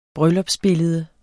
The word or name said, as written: bryllupsbillede